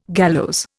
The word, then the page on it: gallows: Indian